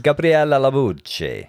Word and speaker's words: Gabriella Labucci: You're mispronouncing it. I'm sorry. It's Gabriella Labucci.